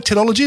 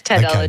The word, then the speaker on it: Tedology: Is that right, Tedology? Have I said that right? – You have, yes, Tedology, okay, good, yep.